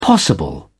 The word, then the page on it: possible: British English